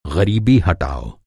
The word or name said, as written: ग़रीबी